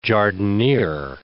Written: jardiniere

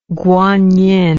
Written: Guanyin

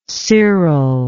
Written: Cyril

